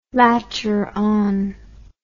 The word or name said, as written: latcheron